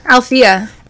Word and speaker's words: Althea: Althea.